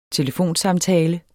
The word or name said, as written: telefonsamtale